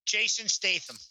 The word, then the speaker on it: Statham: I said, who we got Jason Statham as? How do you say his name? Statham? – Jason Statham.